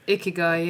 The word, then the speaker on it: Ikigai: There's another one called, I think it's, excuse my pronunciation, Ikigai, which is about how the Japanese... – Oh, I've read that. Ikigai, yeah.